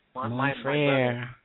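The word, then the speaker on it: Mon frère: The next song I'm going to play is track number 5, Montre. Did I pronounce that right? – Mon frere.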